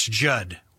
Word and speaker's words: Judd: That's Judd. With two D's, the second D is silent.